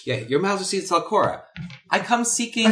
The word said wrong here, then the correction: Zalcore: And she says, hold her pen. "Zalcore." – "Zalcore. – Please pronounce it appropriately."